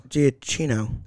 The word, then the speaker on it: Giacchino: Yeah, it wasn't Giacano. – Giannino. – Giacchino. – There you go. I think that's right. But either way, you said it, not me. – Giacano. Okay, yeah, you said it wrong twice.